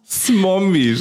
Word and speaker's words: Smombie: Ich kann das gar nicht aussprechen. – Smombie, Schatz.